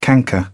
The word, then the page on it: canker: British English; əɹ